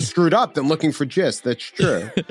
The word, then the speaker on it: Gist: Is it called The Gist? I don't even know anymore. It's just all we go with the out. soft G for the gist.